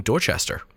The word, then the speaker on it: Dorchester: Now, Mark is the man behind the Hidden Heritage 2016 conference in Dorchester.